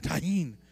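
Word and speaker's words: Tajín: We were in a circle group the other day, and someone brought this stuff called tajine. Is that how you pronounce it? Tajine. Sorry, I apologize. You got to put a little behind it. Tajine.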